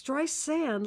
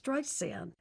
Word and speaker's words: Streisand: My name isn't with a Z. It's Streisand, like sand on the beach. Now, how simple can you get? Sand on the beach, Streisand. So anyway, you know, I decided, how do I change this?